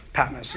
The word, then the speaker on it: Patmos: Patmos.